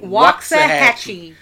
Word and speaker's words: Waxahachie: Waxahachie.